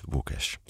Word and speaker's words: Łukasz: Og hvis du vil finde ham, så skal du altså stave Łukasz med L og ikke W, som det godt kan lyde. Men det udtales Łukasz.